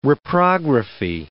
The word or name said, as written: reprography